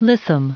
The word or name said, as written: lissom